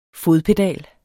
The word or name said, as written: fodpedal